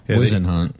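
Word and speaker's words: Whisenhunt: Yeah, Wisenhunt.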